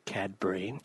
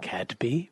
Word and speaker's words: Cadbury: Did you fuck up saying Cadby? – Yeah, I did. And I went to say Cadby, but I said it right.